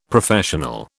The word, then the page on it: Professional: British English